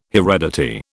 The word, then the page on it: heredity: British English